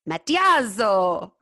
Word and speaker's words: Matiazzo: Welcome, Carla Anita Matiazzo. – Hello. – Is that right? – Yeah, look, it was good. All you need to do is pronounce every letter and you're fine.